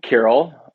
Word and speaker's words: Kiril: do they say Kiril? Sorry to interrupt you. – Carol. I am Carol on every single Starbucks cup ever.